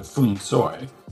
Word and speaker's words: feng shui: So if you listen to a Cantonese movie and hear them say those words, they say something closer to Fung Soi, but no one says that.